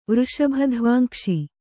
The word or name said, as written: ವೃಷಭಧ್ವಾಂಕ್ಷಿ